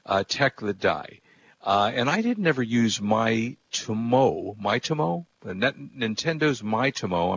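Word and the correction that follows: Miitomo: I'm not sure if I'm pronouncing it correctly. They had a social network. – Remember Nintendo Wii? – Me. – Nintendo MeTomo. – MeTomo. Okay, so the MeTomo social network.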